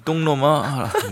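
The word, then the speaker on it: 똥 (懂): 근데 똥놈아라고 발음한 사람들은 틀린 사람들이에요. – 네. – 요거 제가 굉장히 많이 고쳐주는데요. 자, 봐봐요. – 네. – 똥. – 묵직하네요. 깊게, 그렇죠. 묵직하게 하면 네. 이게 된소리가 안 나요. – 똥. – 그렇죠. 똥. – 이게 나올 때도 네. 여기까지 할게요. 여기까지 할게요. 네. 똥. 네. – 그렇죠. 우리가 묵직하게 발음하면 봐요. 똥이 나지 된소리가 날 수가 없어요.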